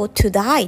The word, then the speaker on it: today: today っていう単語 to die って発音するんでしょと聞かれることがあります。